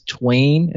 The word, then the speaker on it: TWAIN: Yeah, the Twain, so, the infamous Twain.